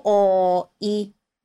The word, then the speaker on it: 多い: O, o, i.